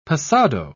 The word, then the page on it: passado: British English